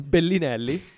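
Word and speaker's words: Belinelli: Can you say that one more time? – Bellinelli. That's just proper pronunciation, kids. You're learning a lot on this podcast already.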